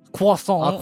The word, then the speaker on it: croissant: You're enjoying a croissant. Mm-hmm. And some... – Excuse me, it's pronounced croissant.